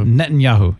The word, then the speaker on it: Netanyahu: Netanyahu.